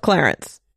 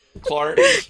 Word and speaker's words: Clarence: The Book of Clarence. – Remember to say the name as two syllables. Clarence. – Clarence. Episode 157, The Book of Clarence.